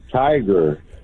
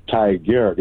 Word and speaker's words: Tigard: it almost sounds like tiger, but it's Tigard. It's like T-I-G-A-R-D.